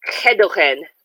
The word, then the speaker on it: gedogen: It's actually pronounced Gedogen. – Gedogen. – Yeah, like you're choking to death.